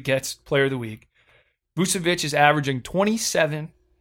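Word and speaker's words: Vučević: Nikola Vucevic. – That's a hell of a name. – Is that his name? I think that's how you say it. You said it right. You nailed it.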